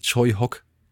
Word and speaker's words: Tsui Hark: Den kennt ihr eher vielleicht geschrieben als Tsui Hark, aber er wird tatsächlich Choi Hock ausgesprochen.